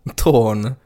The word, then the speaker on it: Toon: Nej, alltså han stavar sitt namn T-O-O-N, men sen har han skrivit inom parentes uttalas med Å. Torn. Torn.